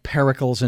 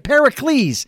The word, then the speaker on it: Pericles: Because the title of the book is, I think it's called Pericles and Me. Did I get that right? – Pericles. – I beg your pardon.